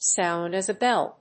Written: sound as a bell

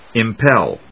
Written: impel

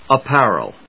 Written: apparel

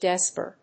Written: desorb

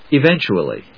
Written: eventually

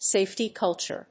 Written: safety culture

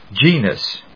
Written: Genus

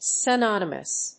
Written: synonymous

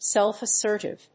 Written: self-assertive

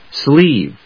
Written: sleeve

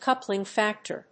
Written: coupling factor

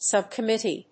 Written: Sub-Committee

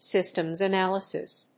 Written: Systems analysis

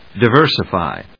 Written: diversify

